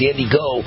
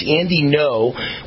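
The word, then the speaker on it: Ngo: I actually thought it was pronounced where the N was silent. It was Andy Go. The G is silent. It's Andy No.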